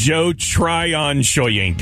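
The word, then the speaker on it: Joe Tryon-Shoyinka: I would say his name correctly, because I did not. – It's Joe Tryon Shoyinka. – There you go. Shoyinka. Even better.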